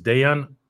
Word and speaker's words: Dejan: Harley, it's the, the full name is Dejan Kovacevic. Uh, the J is pronounced like a Y in Serbian.